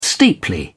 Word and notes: steeply: British English

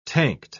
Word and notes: tanked: British English

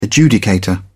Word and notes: adjudicator: British English

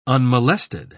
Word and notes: unmolested: British English

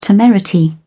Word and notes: temerity: British English

